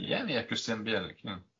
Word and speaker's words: Bielik: Bielik. – Bielik. – Yeah, the stress would be on the first syllable, I think.